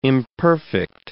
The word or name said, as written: imperfect